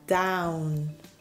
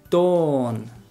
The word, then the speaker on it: dawn: Y yo siempre he pronunciado esta palabra como down. – Down. – Pero down es... – yeah, that doesn't make sense. So the correct pronunciation is dawn.